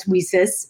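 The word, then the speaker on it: WiCyS: We often go by our acronym, W-Y-C-Y-S, and as Wesys, which is like pronounced like We Sisters.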